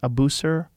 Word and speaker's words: Abusir: Abusir. – Abusir.